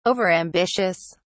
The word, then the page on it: overambitious: British English